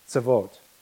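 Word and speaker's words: Tzavot (צבאות): In the Hebrew, it is pronounced Adonai Tzavot, or Yahweh Tzavot.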